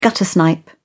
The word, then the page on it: guttersnipe: British English